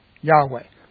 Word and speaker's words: Yahweh: So what we're really focused on is that core of those four consonants, YHWH, proper pronunciation, Yahweh.